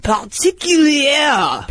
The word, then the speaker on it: particulier: Just if you want to pronounce a French word, just say it in the back of your throat like you're coughing, and that's what it sounds like. – Particulier.